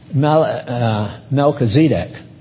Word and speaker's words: Melchizedek: You know, it really should be pronounced Melchizedek. That sounds crazy, but see, in the Hebrew, Melech is king, and Zedek is righteousness, so that's why he's called the king of righteousness, Melchizedek.